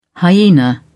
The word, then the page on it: hyaena: British English